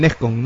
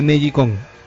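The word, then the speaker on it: NeGcon: Eh, alguna vez lo hablamos con Junior también que es el N-E-G-C-O-N e Acá algunos le decían el Nescon, ¿no? pero eh, en realidad se pronuncia Negicon.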